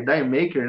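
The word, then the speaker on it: D'yer Mak'er: Que, que eu ficava vendo, cara, que caralho que é Dyer, Dyer Maker, né? Aí eu fui pesquisar. Aquilo ali é um trocadilho com a pronúncia, com a pronúncia inglesa de Jamaica, né?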